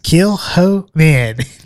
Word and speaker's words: Kilchoman: We have the Kilchoman. – Kilchoman. – Or Kilchoman. And that's Kilchoman. – Kilchoman. That's how I remembered it. Kill the hoe, man.